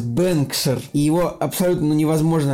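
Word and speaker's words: Bengtskär: потому что оно пишется как Бендскар, произносится как Бэнксер, это такой, ну не знаю этих языков, ты немножко теряешь в этом смысле, но ну, не суть.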